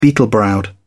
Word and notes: beetle-browed: British English

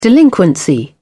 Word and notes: delinquency: British English

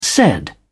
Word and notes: said: British English

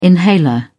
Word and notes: inhaler: British English

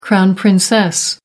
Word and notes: crown princess: American English

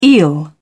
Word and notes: eel: British English

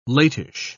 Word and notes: latish: British English

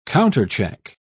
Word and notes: countercheck: British English

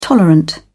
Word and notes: tolerant: British English

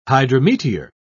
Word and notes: hydrometeor: British English

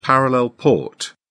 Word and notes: parallel port: British English

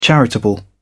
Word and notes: charitable: British English

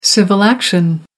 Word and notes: civil action: American English

